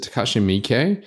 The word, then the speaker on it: Takashi Miike: But is it Takashi Miike? – Yes.